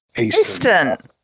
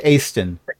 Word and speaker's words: Aeston: This is the Aston farmhold that you're farmhouse that you are holding up in tonight, spelled A-E-S-T-O-N. – Aston. That's a city in my books. – Oh, how about that? – I imagine they frequently have to tell people it's pronounced Aston, the same way that people whose last name is Dumas have to tell people.